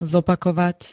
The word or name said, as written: zopakovať